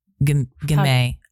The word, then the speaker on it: Gamay: How do you pronounce that in French? Gamay. – Gamay, yeah.